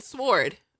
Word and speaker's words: sword: Someone was gifted a giant sword. Oh, sword. That's what you would say in South Africa. Sword. They pronounce the W. But I am not there.